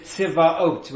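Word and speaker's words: Tzvaot (צבאות): The name in Hebrew, Tzvakot, or as it's pronounced fully, we don't usually pronounce the name outside of the context of prayer or reciting a full verse, but the name is Tzvaot, right?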